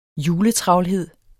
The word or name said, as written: juletravlhed